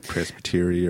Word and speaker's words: presbytery: Presbytery, or I don't know how you pronounce it either.